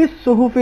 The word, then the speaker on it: الصحف: آیت نمبر 18, اس میں لفی الصحف الاولی, الف لام ادغام ہو جائے گا حرف صاد میں, اور صاد پر تشدید ہے, اس کی توجہ کیجیے.